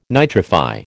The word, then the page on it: nitrify: American English